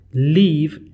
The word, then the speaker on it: leave: Leave L-E-A-V-E یعنی یه جایی رو ترک کردن. این تلفظش فرق میکنه با کلمه live L-I-V-E به معنی زندگی کردن. پس حتما توجه بکنید وقتی که میخواین این کلمه رو تلفظ کنین، leave هست E.